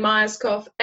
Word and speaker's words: Myerscough: So it's Ishbel, Ishbel, and then Myerscough, Myerscough.